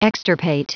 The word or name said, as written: extirpate